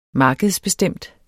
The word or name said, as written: markedsbestemt